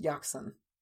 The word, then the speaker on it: Jacqson: J A C Q S O N. And I just knew that it was supposed to be pronounced Yakson.